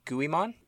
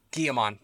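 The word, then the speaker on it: Guilmon: Agumon, Dorumon, Vimon, and Guimon? – Guillemon.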